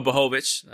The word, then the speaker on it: Błachowicz: Not to say that John Jones can't do it, but we saw what happened with John Blachowicz or Błachowicz. I should say I've been saying his name wrong the whole fucking time.